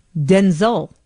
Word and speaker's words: Denzel: pronounced their names Denzel, Z-U-L, with, and it's supposed to be the emphasis on the den. So it's Denzel.